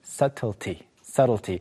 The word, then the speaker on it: subtlety: 자, 처음으로 나오는 것은 미묘함 또는 중요한 세부 요소들을 가리키는 subtlety. B가 발음이 되지 않아요.